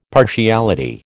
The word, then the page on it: partiality: American English